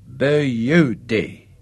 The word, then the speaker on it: beauty: A pause, and then that word of special Aussie approbation. Beauty. But the chief pronounced it in three distinct syllables. Beauty.